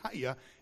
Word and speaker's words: hayah: It's a form of the word the form of the word is pronounced Hayah, if you will, in the Hebrew, but it possesses the same definition as Yahweh.